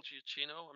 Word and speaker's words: Giacchino: No, it's the other guy that I like that's kind of a modern guy is Michael Giacchino.